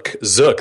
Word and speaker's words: Zook: Zook. That's a Z, my friend.